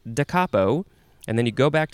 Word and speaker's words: da capo: Okay, our next word is, I think it's pronounced da capo. Da capo. Two words. D-A is the first word, and then C-A-P-O. It's not capo like I thought it would be.